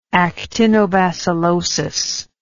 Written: actinobacillosis

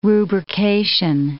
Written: rubrication

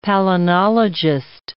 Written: palynologist

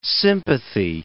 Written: sympathy

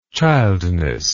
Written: childness

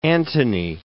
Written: Antony

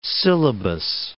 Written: syllabus

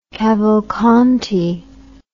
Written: Cavalcanti

